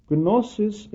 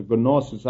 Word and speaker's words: gnosis: Gnosis in the Greek language means knowledge, and I am purposely pronouncing it as a gnosis. Actually, in the English language, the G is not pronounced, so it would be gnosis.